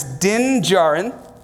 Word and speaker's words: Din Djarin: This here is Din Djarin. Did I say that right? Yep. Awesome.